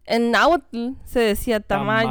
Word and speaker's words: tamalli: Y eso sí, y en náhuatl, tamal se decía tamali. Pero ah. T-A-M-A-L-L-I. – L-L-I? – Yeah, tamali.